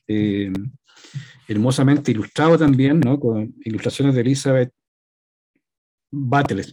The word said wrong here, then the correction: Builes: ¿Se pronuncia así o Butles? – no, Elizabeth Builes, Elizabeth Builes,